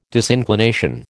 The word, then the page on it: disinclination: American English